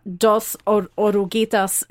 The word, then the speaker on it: Dos Oruguitas: I'm going to go Lin-Manuel Miranda, Dos Oruguitas.